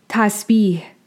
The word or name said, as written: Tasbīḥ